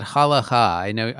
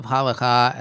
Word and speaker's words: Halakhah: I guess I'm gonna say it Halakhah. I know you can pronounce it a couple different ways. I think of Halakhah as Jewish law, essentially what the ancient rabbis or those who've interpreted them say you can do and you can't do.